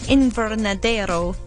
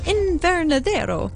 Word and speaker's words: Invernadero: Years of conservation work in the Invernadero, I didn't pronounce that very well, Invernadero, there we go, National Park, have allowed it to become a suitable habitat for the brown bear.